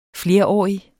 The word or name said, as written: flerårig